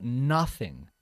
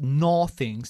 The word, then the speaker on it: nothing: Cuando la palabra nada es el sujeto de la frase, la traducimos como nothing. Y una cosa muy importante es pronunciarlo bien. No decimos nothing, sino nothing. Nothing, bajando la mandíbula.